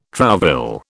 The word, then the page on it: trouvaille: British English